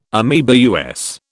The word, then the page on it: ameba: American English